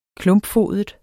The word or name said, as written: klumpfodet